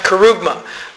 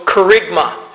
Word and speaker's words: kerygma: Now, depending on the type of speech, but the type of word, it's pronounced kerugma. But in the Greek, it's also kerygma. And I'll just call it kerygma from now on because I'm most used to that.